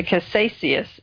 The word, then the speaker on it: caduceus: And this symbol, the caduceus is how you say it.